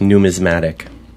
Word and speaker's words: numismatic: And I think it's pronounced numismatic, just to be clear.